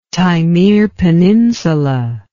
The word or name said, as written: Taimyr Peninsula